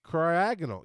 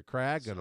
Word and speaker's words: Cryogonal: Cryogonal. Yeah. So, again, the second syllable is the emphasis there. Cryogonal.